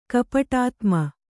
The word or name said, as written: ಕಪಟಾತ್ಮ